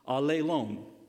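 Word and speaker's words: alelon (ἀλλήλων): It's my favorite because I find it, like, in my, you know, sense of humor, I find it mildly funny because it's pronounced alelon, alelon, which sounds a lot like all alone to me.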